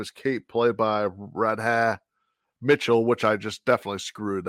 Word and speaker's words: Radha Mitchell: It's just Radha Mitchell. Simple as that, Radha Mitchell.